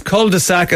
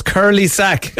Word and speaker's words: cul-de-sac: I worked with a guy that pronounced cul-de-sac as curly sack.